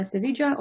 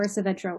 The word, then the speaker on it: Savedra: Isabella Savedra or Savedra works.